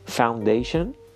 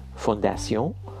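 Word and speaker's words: foundation: البته فاندیشن به جای فونداسیون ولی هرگز فونداسیون ما نداریم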